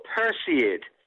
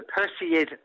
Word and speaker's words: Perseid: Yeah, it's called Perseid, the Perseid meteor shower.